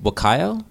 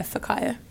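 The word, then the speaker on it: Whakaio: What about, you mentioned Wakayo, saying his name right? – Yep. – Yeah, Fakayo. – So it's like an F sound? – W-H pronounces an F. He's a native New Zealander.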